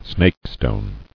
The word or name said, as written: Snakestone